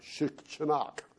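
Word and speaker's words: shichinok (щенок): To be fair, the Russian word for puppy, as best I can determine, is pronounced something like shichinok.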